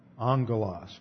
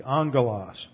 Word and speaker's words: angelos: Here it is in the Greek, angelos. This double gamma here is always translated as an NG, or pronounced as an NG, angelos.